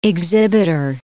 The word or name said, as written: exhibitor